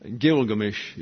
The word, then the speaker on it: Gilgamesh: Gilgamesh is the right pronunciation, and Stephen Mitchell has pulled all this together.